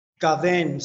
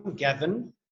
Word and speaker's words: Gaven: Gaven. So it's Scottish, the way you pronounce the name or spell the name. So it's not traditional G-A-V-I-N, Gaven. It's Gaven.